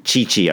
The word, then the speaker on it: Tite: By the way, Damon, Tite, I learned that from Tim Vickery. That's how you pronounce his name.